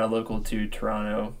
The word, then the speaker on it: Toronto: Don't say Toronto. It's Toronto. There's no T. – It's like that. That's how it is with Atlanta. It's like if you pronounce the second T, you're a cop.